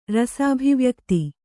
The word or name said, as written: ರಸಾಭಿವ್ಯಕ್ತಿ